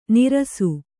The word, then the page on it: ನೆರಸು: kannada